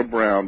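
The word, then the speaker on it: Broun: No, it's B-R-O-U-N. It's a U instead of the usual W for brown. – Okay, fine. – But it's pronounced like the color brown, but it's B-R-O-U-N.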